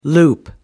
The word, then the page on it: loop: American English